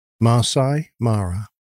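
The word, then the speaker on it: Maasai Mara: I think it's the Masai Mara is the way it's generally pronounced. – How again? – Masai Mara.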